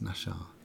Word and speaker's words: Naxxar: I'm just going to take the also the opportunity to say that she was born in Nashar, even though it's spelled N A X X A R, and you wouldn't think it was, but it's Nashar.